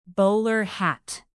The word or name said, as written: bowler hat